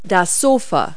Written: sofa

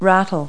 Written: rattle

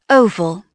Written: oval

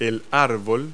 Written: tree

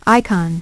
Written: icon

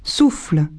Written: souffles

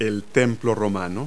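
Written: Roman temple

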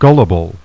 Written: GULL

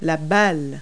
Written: ball

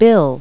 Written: bill

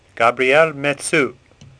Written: Metsu

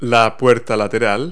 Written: side door

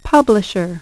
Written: publisher

